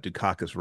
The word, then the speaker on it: Dukakis: And you said it right is the ironic part. You actually pronounced it correctly. There was a lot of stuff that you mispronounced, but you got Dukakis right the first try. – How else would you say it though? – Well, exactly. It's one of the words. It's written how it's pronounced.